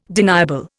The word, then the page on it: deniable: Indian